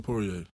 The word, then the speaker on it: Poirier: Poirier? – Dustin Poirier.